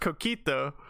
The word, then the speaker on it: coquito: It's actually pronounced Coquito, and it is. Yeah, it's a Puerto Rican thing.